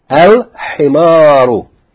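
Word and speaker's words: al-himaru (الحمار): Al-himaru. So this word begins with ha, and ha is from the huruf, qamariya, so we see the lam is pronounced.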